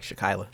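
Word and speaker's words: Shakyla: How do you pronounce her first name? – Like Shekyla.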